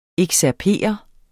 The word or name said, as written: excerpere